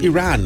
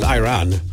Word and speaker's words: Iran: Well, actually, it is pretty far away, but it's pronounced Iran, not Iran.